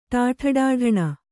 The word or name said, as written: ಟಾಠಡಾಢಣ